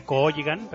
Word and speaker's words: Cooligan: Y como siempre, gracias a los amigos de Cooligan, dos O's y dos L's, acordaros, se escribe Cooligan, pero se dice Cooligan,